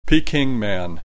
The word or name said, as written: Peking Man